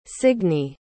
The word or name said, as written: signe